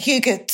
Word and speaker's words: hygge: I think that's how you pronounce it. It's spelled Hygge, but Hygge, is it? – Yes.